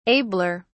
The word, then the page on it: abler: British English